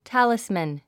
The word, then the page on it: talisman: tǽlismən; タァリスマン